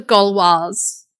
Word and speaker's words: Gauloise: So if you have the Gauloise, I said it properly that time. – You did. Yes. Well done, you.